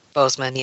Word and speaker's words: Boseman: Am I saying it right? – Boseman, yeah.